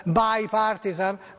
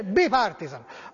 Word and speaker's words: bipartisan: Che ci pensi te a spiegare ai nostri giornalisti che bipartisan non è inglese e quindi non si pronuncia bipartisan? Grazie, Spice Max Pescia. Ai nostri giornalisti, nostri quelli di qui? Non so, non credo, spero che questi almeno nostri non sbaglino. Ecco, comunque si dice bipartisan, ma Di Pietro, che ha sbagliato la pronuncia di Waterloo, volendo correggerlo, chi aveva sbagliato prima di lui, l'hai sentito?